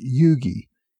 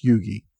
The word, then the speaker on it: Huger: Several people pointed out that his last name is actually pronounced Yugi. Even though it's spelled H-U-G-E-R, it's pronounced Yugi.